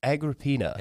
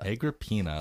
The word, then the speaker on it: Agrippina: I believe we decided it is Agrippina. – Agrippina. – We literally the first time, both of us looked up how to pronounce this name and got four different pronunciations. So we're going with the one that we heard the most.